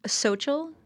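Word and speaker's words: Xochitl: How do you pronounce her name? – Xochel? I'm assuming. – Yeah. Okay. You got it.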